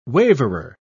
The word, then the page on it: waverer: British English